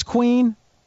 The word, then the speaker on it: Queen: Oh, I thought it was pronounced like Queenin or something. All right, it's Queen. All right.